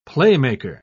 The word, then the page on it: playmaker: British English